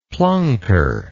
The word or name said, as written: plunker